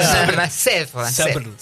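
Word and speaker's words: Sèvres: El acentito, le erraste con el acento. Sèvre. – Sí. ¿Qué es Sèvre? Hay un centro internacional de estudios pedagógicos en la localidad de Sèvre, que es en el conurbano de París, y la beca mía era para un ciclo lectivo, o sea, 9 meses.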